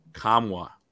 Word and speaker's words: Nkamhoua: Former Tennessee por- uh, forward Olivier Nkamhoua, uh, which is how you pronounce it because I researched it, uh, spelled N K A M H O U A.